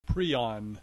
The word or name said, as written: prion